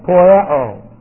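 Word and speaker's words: poieo (ποιέω): That's poieo. And I just put it on the board one time. P-O-I-E long O.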